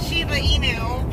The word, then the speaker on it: Shiba Inu: Little chestnut, the Shiba Inu.